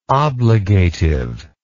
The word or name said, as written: obligative